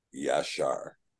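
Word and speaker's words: Yashar (ישר): Now, Yod, Shin, Resh is very clearly and very obviously, and Strong's will tell you, is pronounced Yashar.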